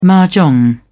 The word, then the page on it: mah jong: British English